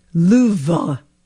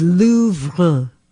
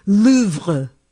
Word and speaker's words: Louvre: I'm not saying our French teachers were the best, but clearly I was learning it incorrectly, because Babbel is reminding everyone that the correct pronunciation for the famed museum is Louvre, with a very soft pronunciation of the R at the end. So it's Louvre, something like that.